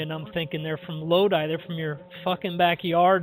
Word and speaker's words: Lodi: how often do you hear the name Lodi pronounced incorrectly? – Lodi. – Lodi, yeah. – That's what we hear is Lodi.